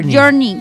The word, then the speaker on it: Journey: Desde la ciudad natal del vocalista de la banda Journey, ¿se dice así? – Journey.